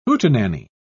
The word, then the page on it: hootenanny: British English